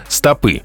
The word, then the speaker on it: стопы: С удивлением узнал, что слово «стопа», нижняя часть ноги, в именительном падеже во множественном числе должно произноситься с ударением на последний слог. «Стопы».